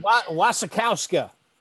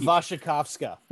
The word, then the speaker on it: Wasikowska: Wasikowska.